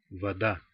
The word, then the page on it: вода: [vɐ.ˈda]